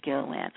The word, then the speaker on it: Gilliland: And I say James Gilliland. I say it's a hard G, but you say Julie.